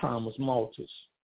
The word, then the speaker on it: Malthus: There's this philosophy referred to as Malthusianism after some honky who lived 200 years ago named Thomas Malthus, M-A-L-T-H-U-S. I don't think the H is pronounced, or some may pronounce it Thomas Malthus.